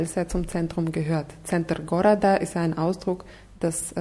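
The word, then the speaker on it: Center (центр): Das Wort Center wird mit Z ausgesprochen. Center.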